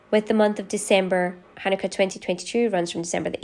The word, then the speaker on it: Hanukkah: And it's, yeah, it is pronounced with a guttural KH sound, like Hanukkah, okay.